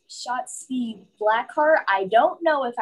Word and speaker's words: Shotzi: Yelling Yahtzee, but Shotzi. – Yeah. – Shotzi. That's how we're just gonna say her name from now on. We're just gonna scream it like we would scream Yahtzee.